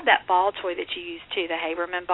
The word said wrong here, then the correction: Hoberman: ball. Am I saying that correctly? – I think it's Hoberman, but I'm not Hoberman.